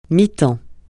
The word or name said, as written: mi-temps